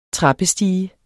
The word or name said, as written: trappestige